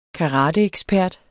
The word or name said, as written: karateekspert